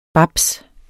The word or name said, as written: babs